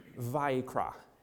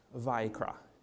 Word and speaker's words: Vayikra: So it's pronounced Vayikra, Vayikra.